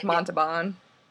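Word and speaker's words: Montalbán: I always heard it pronounced Montalban. Like the L is kind of silent.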